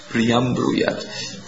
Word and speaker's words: priyam bruyat: And Prabhupada said, forgive our devotees, they don't know satyam bruyam priyat bruyam. That means to priyam bruyat. Sorry, I said it wrong. I want to say it correctly. Satyam bruyat, priyam bruyat.